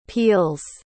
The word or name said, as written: peels